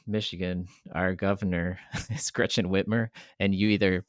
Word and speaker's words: Whitmer: I'm not getting into politics, but nonetheless, people mispronounce her name Whitmore all the time. So I think it would be a lot of fun to just confuse the shit out of people to have Cam Whitmore, and then Governor Whitmer. Everybody pronounces incorrectly Whitmore.